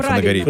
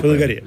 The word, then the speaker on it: Фанагория: Вы знаете, это произношение греческое. С одной стороны, Фанагория правильно, если там будет в конце дифтонг стоять. Если дифтонга «е» «и» не будет, а будет «и», будет Фанагория. Но мне нравится Фанагория, более благозвучно.